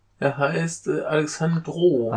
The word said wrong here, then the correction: Alejandro: Alejandro. Äh, Alejandro. Alejandro Chodorowski.